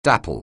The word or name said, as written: dappled